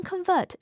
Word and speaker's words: convert: 动词时常用来表示转变、改变、改装或转换，比如把测量单位磅转换成公斤， convert pounds into kilograms。它还可以表示思想或信仰的改变，比如他信佛教了， He has converted to Buddhism。当 convert 是名词的时候，它是指改变了信仰的人。需要注意的是，发音有所不同，是 convert。